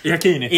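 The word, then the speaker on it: Iachini: Ja, det verkar inte vända för Janacis gubbar där. Iakini. Iakini, förlåt. Det uttalet är inte på sin rätt idag.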